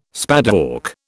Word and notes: spadework: British English

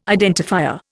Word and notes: identifier: Indian

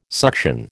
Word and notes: suction: American English

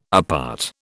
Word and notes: apart: British English

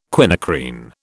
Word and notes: quinacrine: British English

